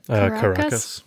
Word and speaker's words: Caracas: Caracas.